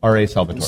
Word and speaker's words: Salvatore: R.A. Salvatore.